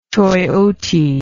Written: choyote

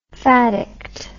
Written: faddict

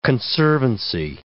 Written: conservancy

